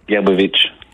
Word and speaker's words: Gerbovitch: Comment on prononce votre nom de famille, Ivan? – Gerbovitch. – Gabovitch. – Gerbovitch, oui, comme ah, okay. c'est beau, c'est Vitch. Gerbovitch.